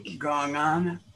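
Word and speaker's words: gong'an: What will help is to take this, how do you say that? – Gong on.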